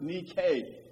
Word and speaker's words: Nike: It's a form of the Greek word nike. And in English, we spell that N-I-K-E, and we pronounce it Nike.